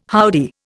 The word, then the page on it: howdy: Indian